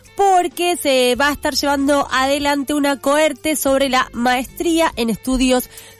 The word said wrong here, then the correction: cohorte: Y para hablar en profundidad de, primero, si lo dije bien, si se dice cohorte. – Cohorte. – Cohorte con doble. Cohorte.